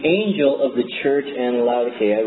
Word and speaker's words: Laodicea: See the Greek there? See the little K? Went from Greek K to a Latin hard C, which got translated into English. And for some reason... For some reason, it became soft. Anyway, the proper pronunciation is Laodicea.